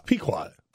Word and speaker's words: Pequot: He goes, yeah, it's a Pukyok. And I said, what? I said, I don't think that's how you pronounce it. And can you say it? You know the tower I'm talking about. – It's Pequot.